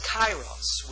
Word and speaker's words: kairos: But this word is kairos. We would spell it K-A-I-R-O-S, kairos.